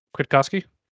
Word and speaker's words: Kwiatkowski: Kowatski. – Kwiatkowski? – Sure. – You say it quickly and it sounds right. – quick ski. it sounds like quick skis.